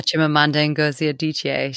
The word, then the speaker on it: Chimamanda Ngozi Adichie: Yeah, Chimamanda Ngozi Adichie.